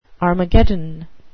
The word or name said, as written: armageddon